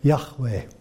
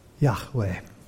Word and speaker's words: Yahweh: And it is commonly pronounced, and I'm sure I won't be like a full Jew in saying this, but something with the way of Yahweh. Yahweh.